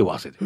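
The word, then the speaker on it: 早生: はい、和瀬は早く生まれると書いて和瀬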